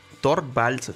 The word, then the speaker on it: Torvalds: Entonces, esta nota dice: A Linux Torvalds Torvalds, sí, lo dije bien.